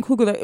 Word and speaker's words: Coogler: But with Black Panther, I also think we have to just really talk about Ryan Coogler. Is that how you pronounce his name? – Yes, Ryan Coogler.